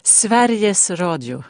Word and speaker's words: Sveriges Radio: Je ne suis pas certain d'avoir bien prononcé Sveriges Radio. – Sveriges Radio. – Ah oui, on en est loin déjà.